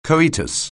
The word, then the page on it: coitus: anglais